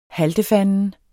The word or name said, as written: haltefanden